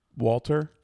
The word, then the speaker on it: Walter: Say it right. – Walter? – Walter. – I'm not German.